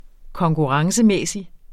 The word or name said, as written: konkurrencemæssig